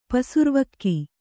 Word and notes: ಪಸುರ್ವಕ್ಕಿ: pasurvakki

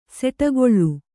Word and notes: ಸಿಡಿಗೊಳ್ಳು: kannada

